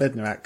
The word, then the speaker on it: Bednarek: Bednarek, because he's been starting the last few games.